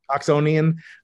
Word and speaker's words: Oxonian: which your fellow Oxfordian, is that how you say it, Frank? Oxfordian or Oxonian? – Oxonian, Sir Roger Penrose, multi-time guest on the podcast, fellow Nobel Prize winner with Peter Higgs.